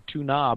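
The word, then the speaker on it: Túna: which is Tuna, T-U-N-A, and even puts the stress on the U, so that, you know, sometimes people will pronounce it and want to make it sound fancier by calling it Tuna, but it's clearly supposed to be pronounced Tuna.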